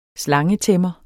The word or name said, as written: slangetæmmer